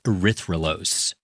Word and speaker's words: erythrulose: erythrulose.